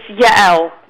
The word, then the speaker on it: Yael: Oh, it's Yael. – Yael. – Yeah. – Okay. – Two syllables.